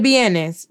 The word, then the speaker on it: vienes: So you say, de dónde eres, or de dónde vienes, that V is pronounced like a B.